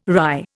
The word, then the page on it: rye: Indian